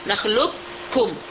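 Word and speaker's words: nakhlukkum (نخلقكم): Salah satu idgham kamil, qaf itu kita hilangkan sama sekali, kita baca kaf nakhlukkum.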